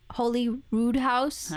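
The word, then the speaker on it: Holyroodhouse: R-O-O-D Rude house? – House Holy Rood House.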